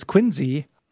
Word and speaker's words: Quincy: Folks here in Mass, especially in the eastern half of the state, have a lot of idiosyncratic pronunciations. Quincy is Quincy, Woburn is Woburn, Peabody is Peabody.